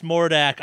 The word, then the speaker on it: Mordak: It's pronounced Mordak. I hate everyone who reads it wrong. Like, it has become a fascinating age test of people. Like, hey, Mord, okay, Mord 4K. Ah, you're fucking like 13.